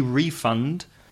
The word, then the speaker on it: refund: a refund, the stress is on the second syllable. Okay. My mistake. I'm sorry. The stress is on the first syllable, refund, to ask for a refund. And if it's a verb, the spelling is the same, but change the stress to the second syllable.